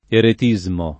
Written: eretismo

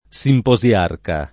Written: simposiarca

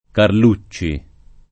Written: Carlucci

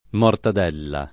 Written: mortadella

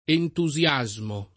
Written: entusiasmo

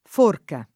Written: forca

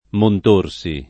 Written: Montorsi

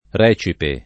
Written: recipe

